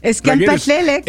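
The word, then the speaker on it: Es'kia Mphahlele: Eskia Mpahlele, correct.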